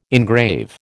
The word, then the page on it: engrave: American English